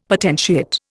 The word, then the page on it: potentiate: Indian